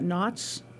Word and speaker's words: nautes: Oh, the word astronaut combines the Greek roots astron and N-A-U-T-E-S. How would you pronounce that? Nauts? – Sure. – So you take those. So astron means star and nauts or nautes, sounds French, means sailor, translating to star sailor.